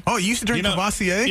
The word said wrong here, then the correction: Courvoisier: Yeah, you know Matt makes fun of me if I mispronounce. But I know he has a little dyslexia too. If I mispronounce a word. Mm-hmm. Were you always able to pronounce Courvoisier when you saw it? – Courvoisier. – When you first saw Courvoisier.